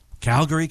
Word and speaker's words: Calgary: So once and for all, what is the correct pronunciation of the town? – Calgary, Canada. – So you kind of slur it together, Calgary.